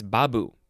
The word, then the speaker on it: Babu: In Hindu, they say Babu, or is it, it's Babu. The emphasis is on the first syllable.